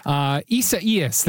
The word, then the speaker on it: Isaias: Isaias, there it is.